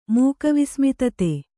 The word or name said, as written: ಮೂಕವಿಸ್ಮಿತತೆ